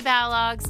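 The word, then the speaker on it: Balogh: on Twitter made sure that we understood that it is not, I've been pronouncing Mary Balog's name wrong forever. – For a lifetime, my lifetime. It is pronounced Balog.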